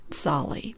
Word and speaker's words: Tsali: That is spelled T-S-A-L-I, Solly.